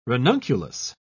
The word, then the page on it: ranunculus: British English